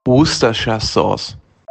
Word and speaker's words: Worcestershire: Worcestershire-Sauce.